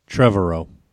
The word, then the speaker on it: Trevorrow: It's Colin Trevorrow? – Trevorrow. – Okay, that's how you say his name.